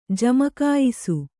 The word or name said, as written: ಜಮಕಾಯಿಸು